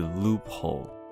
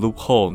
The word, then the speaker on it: loophole: 叫做 loophole，loophole，又是两个字组在一起的哈，loophole，l o o p h o l e，loop 加上 hole 合成一个字，loophole，算是一种复合名词吧，所以也符合我们之前跟大家提过的一个重要概念，就是复合名词的重音放在前面哦，所以是 loophole，很多人就会下意识的念错，念成什么 loophole，loophole，这样听起来就两个字都加重了。那你仔细去听，人家其实念出来的声音是 loophole，loophole 这样子的声音。所以如果你都念成 loophole，你当然很有可能会听不到别人讲 loophole，因为在你耳中，在你脑中的认知是不同的声音，要注意啦。